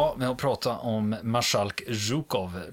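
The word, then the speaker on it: Zhukov (Жуков): De, flera av er kände, jag får fråga Niklas här, hur var det med uttalet? – Jo, det var eh, godkänt. Zhukov. Zhukov. – Ja, ja, det är godkänt.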